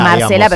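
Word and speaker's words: Marcella: Marcela se escribe Marcelia. Marcela, pero claro, se dice Marcela.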